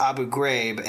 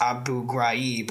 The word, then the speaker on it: Abu Ghraib: I think it's pronounced Abu Ghraib, and Johnny says it's pronounced Abu Ghraib, which literally no one ever says it that way. – Look, I'm not saying that everybody says Ghraib. I'm just saying it sounds way more Middle Eastern.